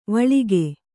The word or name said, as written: ವಳಕು